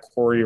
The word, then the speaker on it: Corey: My Twitter is at Corey Writing. My name is spelled C-O-R-E-Y, and then writing is like the other part of the handle.